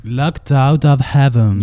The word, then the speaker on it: Locked Out of Heaven: Se llama Locked Out of Heaven. Voy a pronunciar mejor el inglés. – Locked Out of Heaven.